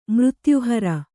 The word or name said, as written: ಮೃತ್ಯುಹರ